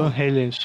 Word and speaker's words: Van Halen: Van Halen, som de E, né? – É, um som quase um E, um E. Porque você não vai falar Van Halen, Van não tem. Você vai falar Van Halen ou Van Halen. São duas pronúncias é.